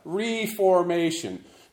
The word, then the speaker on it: reformation: And notice that I emphasize the first syllable of that, reformation, to emphasize that it is a verb, something that is happening now rather than something that happened in the past, you know, the Protestant Reformation.